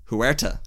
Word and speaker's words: Huerta: But again, a good superhero movie needs a good villain, and Teinoch Huat, how do you pronounce it? I'm so sorry. – Horatio, is it? – No, Teinoch Huerta. – Huerta, yeah.